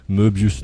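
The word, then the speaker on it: Möbius: ben ça s'écrit M-O-Tréma, en allemand, hein, O-Tréma, ça se prononce E, Moebius.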